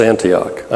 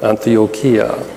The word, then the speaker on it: Antioquia: Yeah, last week I mispronounced Antioch, Antioquia.